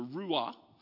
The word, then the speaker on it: ruach: The word is ruah, which I'm probably pronouncing wrong because I don't speak Hebrew, but it's spelled R-U-A-C-H, ruach or ruah.